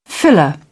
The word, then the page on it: filler: British English